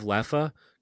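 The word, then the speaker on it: Leffe: I went to the liquor store and I was like, oh, do you have Leffa? Because that's how you pronounce it. The E's are like A's, you know?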